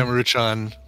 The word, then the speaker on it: Maruchan: So it's even that brand, that Manchurian. – Yeah, Maruchan.